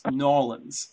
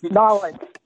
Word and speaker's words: New Orleans: which is located in a city that's spelled New Orleans, but I believe is pronounced Nolens. – Nolens. That's right.